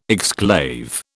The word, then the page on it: exclave: British English